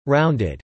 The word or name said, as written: rounded